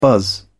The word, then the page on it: buzz: British English